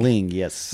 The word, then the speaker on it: Ling: Lisa Ling. – Ling, yes.